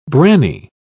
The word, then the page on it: branny: British English